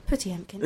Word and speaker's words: Potemkin: So something that makes me think, you know, of maybe literary or pop culture references would be your song Potemkin, or as my former Russian theatre professor would call it, Putiemkin. you're saying it wrong.